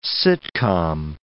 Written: sitcom

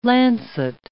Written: lancet

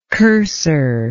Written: cursor

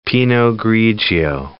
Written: pinot grigio

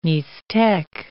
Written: Mixtec

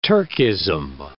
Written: Turkism

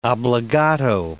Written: obbligato

